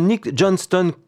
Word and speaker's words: Nick Johnston: Nick Johnston, comme ça se prononce, J-O-H-N-S-T-O-N.